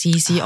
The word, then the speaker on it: Sissi: mit einem sehr weichen S Sissi ausgesprochen.